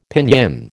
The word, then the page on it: Pinyin: American English